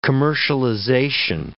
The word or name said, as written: commercialization